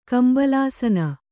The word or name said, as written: ಕಂಬಳಾಸನ